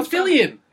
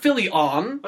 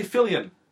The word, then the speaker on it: Fillion: No, Nathan Fillion. It's not Fillion. – Okay, Fillion. – There you go.